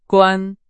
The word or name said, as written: 关